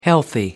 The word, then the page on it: healthy: American English